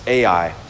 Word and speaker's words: Ai: Now Joshua sent from Jericho to Ai, that's how it's pronounced, Ai, which is near Beth Avon, east of Bethel, and said to them, go up and spy out the land.